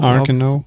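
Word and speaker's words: Arceneaux: Oh Gott, wie wird es nochmal ausgesprochen? Arz Arkano? – Arkano, genau. Arkano, genau.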